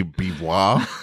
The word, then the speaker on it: Beauvoir: Pretty sure it's Beauvoir. – sounds right. – It's French. – Is it V-O-I-R? – Yeah.